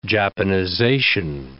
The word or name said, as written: Japanization